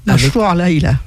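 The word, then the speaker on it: Mashrou' Leila: Mashrou Leila.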